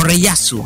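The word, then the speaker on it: Rayearth: Magic Knight Rayer, o Reyasu, como se, pronuncia en japonés.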